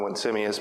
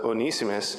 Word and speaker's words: Onesimus: I always want to call him Onesimus, but his name is pronounced uh, Onesimus.